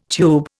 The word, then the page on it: tube: Indian